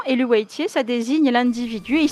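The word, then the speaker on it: Eluveitie: Alors moi, je le prononce Elouaitier.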